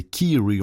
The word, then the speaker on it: Kyrie: Tout à l'heure, j'ai dit Kyrie. Non, non, c'est Kiri, on l'entend bien. Il le prononce comme ça, hein, Kiri. K-Y-R-I-E, Kiri.